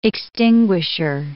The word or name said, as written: extinguisher